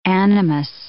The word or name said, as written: animus